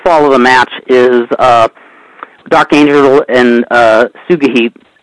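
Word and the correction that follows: Sugehit: Am I saying her name right, Sugahit? – Yeah, Suhei. – Suhei, okay. They spelled it S-U-G-E-H-I-T. I thought it was spelled differently, but... – Yeah, they spell it like seven different ways. – Okay, so the way they spelled it, I thought her name was Suge Hit. – Yeah, just say Suhei.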